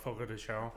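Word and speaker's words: Fogo de Chão: But that's how I got the Boca. What is it? – Foco de Chao.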